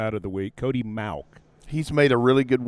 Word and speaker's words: Mauch: Mike, how do you say Cody's last name? Mauch. – Mauch. Cody. Yeah, that's why. Mauch? That's why I asked. It's, it's Mauch. Cody Mauch.